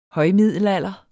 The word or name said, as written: højmiddelalder